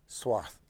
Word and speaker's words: Swart: It's S-W-A-R-T, but it's pronounced Swath, like it's a T-H.